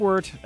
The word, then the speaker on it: Wort: Type in the Wort, spelled Wort, pronounced Wort, a podcast.